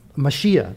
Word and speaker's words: Mashiach: It's Mashiach. That's what it is.